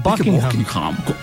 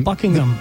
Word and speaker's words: Buckingham: Es Buckingham. Eso y de hecho, inglés perfecto, el H medio que se es medio silencio. Buckingham.